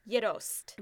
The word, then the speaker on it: Gjetost: It's called Yerost. – Never heard of it. – Yerost is spelled G-J-E-T-O-S-T.